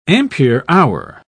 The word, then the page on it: ampere-hour: British English